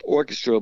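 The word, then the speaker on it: Arkestra: I guess it's pronounced orchestra, but it's, you know, spelled funny.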